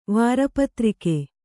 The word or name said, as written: ವಾರಪತ್ರಿಕೆ